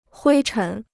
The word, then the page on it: 灰尘: huī chén